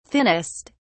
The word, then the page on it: thinnest: British English